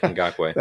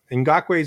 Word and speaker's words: Ngakoue: That's Yannick Ngakoue.